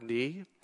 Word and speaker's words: knee: Why do we say have a K in front of uh, in the word knee? at the time of the printing press, you would say "knee," right? Like you say in Dutch and German. It's only because our our pronunciation has changed over time, eh, but the word spelling has remained the same.